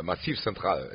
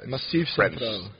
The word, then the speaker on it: Massif Central: Am I pronouncing that right? – Massif Central. – Massif Central. – French pronounced.